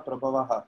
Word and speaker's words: prabhavaha: I want to thank you for pronouncing the Visarga. Jagataha Prabhavaha. It is very good. Somebody reads Sanskrit properly.